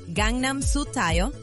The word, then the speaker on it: Gangnam Style: Zapopan Gangnam Style. – Acabamos de escuchar a PSY. ¿Se acuerdan del fenómeno que causó hace años? Resulta que el video de Gangnam Style, con su pronunciación original sería Gangnam Style, rompió el contador de visitas de YouTube en el 2014.